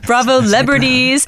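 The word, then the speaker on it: Bravolebrities: Would you they call y'all Bravo Lebrities? Is that what it is? Did I say it right? – Yeah, you said it right. Say it loud. – Bravo Lebrities.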